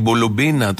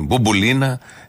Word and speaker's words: Μπουμπουλίνα: τον Κολοκοτρώνη και στην Μπουμπουλίνα, την είπε Μπουλουμπίνα, και αυτό θα μείνει από τον Κάρολο, γιατί τι άλλο να μείνει βασιλιάς, είναι έτσι κι αλλιώς.